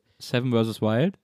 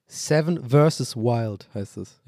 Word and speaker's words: Seven vs. Wild: Seven vs. Wild heißt es. – Ich habe doch Versus gesagt. – Nee, hast du nicht.